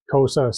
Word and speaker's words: Xhosa: They're an offshoot of the Zulus, and a lot of their language is the Xhosa language. And even Xhosa itself, which is spelled X-H-O-S-A, it's a click sound like Xhosa.